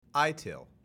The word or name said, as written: ITIL